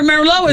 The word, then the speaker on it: Merlot: Yeah, remember Merlot is, and that T is silent on the end of Merlot.